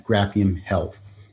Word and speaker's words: Graphium Health: and now you're the CEO and founder at Graphium Health, if I said that correctly.